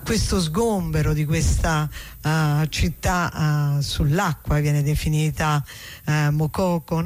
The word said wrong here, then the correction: Makoko: Mokoko con l'accento? – Makoko, sì, non so se con l'accento finale alla francese è Makoko, però probabilmente siccome quello è un paese anglofono sarà in maniera diversa.